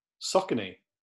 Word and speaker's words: Saucony: Saucony? – Saucony. – Saucony? – Yes. – Saucony.